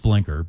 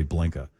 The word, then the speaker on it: blinker: It's pronounced blinker, but we all know it's really blinka here in Massachusetts.